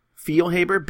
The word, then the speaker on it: Feilhaber: But it's almost like field, but no D. Feilhaber, Benny Feilhaber.